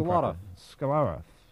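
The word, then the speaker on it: Skolyarov: Skolarov.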